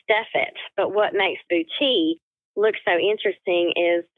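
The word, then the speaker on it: boutis: Talking about new techniques, I have recently learned how to do bucci.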